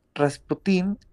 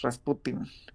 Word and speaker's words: Rasputin: Rasputin es una transcripción del francés, pero la, mm, la pronunciación correcta del ruso es Rasputin.